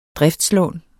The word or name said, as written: driftslån